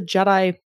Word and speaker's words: GEDI: So it's GEDI with a G, but pronounced a la Star Wars, as you pointed out.